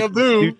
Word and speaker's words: Abu: I'm going to go with Apu. – All right. Show me Apu. You mean Abu, by the way. – Abu. – You're right. I did say it wrong. You're right. It's Abu.